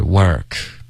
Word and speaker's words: work: Work 的这个发音不对，是 work.